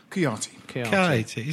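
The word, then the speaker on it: Kouyaté: How did you pronounce his name? Kawati. – Kuyati. – Is that how you pronounce it? – Kuyati.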